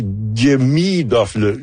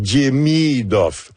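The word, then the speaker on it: Demidov: Il y a un petit Demidoff. L'accent est sur la deuxième syllabe. Donc, Demidoff.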